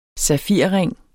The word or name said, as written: safirring